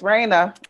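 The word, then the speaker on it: Rana: It's Raina.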